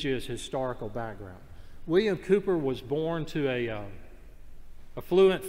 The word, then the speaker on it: Cowper: And it involves a man named William Cooper. We pronounce it Cooper, but it's spelled C-O-W-P-E-R, like Cowper.